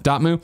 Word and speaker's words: Dotemu: I'm going to say Dotemu. How do you say it? It's French. – No, I like that. – Dotemu.